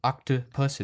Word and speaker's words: octopus: So when you say octopus, that middle vowel, the middle O, is just octopus. It's really short. If you replaced it with any one of our vowels, we'd still pronounce it octopus.